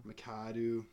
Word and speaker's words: McAdoo: Yeah, so Ben McAdoo or McDoo or McAdoo, I'm not sure how to pronounce his name. – McAdoo, whatever it is, Mr. Haircut.